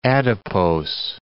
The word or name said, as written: adipose